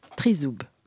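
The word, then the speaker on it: trizoub (тризуб): Le trident se prononce trizoub en ukrainien et c'est le nom de l'association franco-ukrainienne de Nantes que nous accueillons ce soir.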